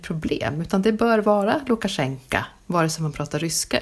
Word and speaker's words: Lukashenka: Men då är det så att, det är lite krångligt, ett obetonat O i slutet på ett ord så här, uttalas som A på ryska. Så att uttalet blir Lukashenka om man pratar ryska.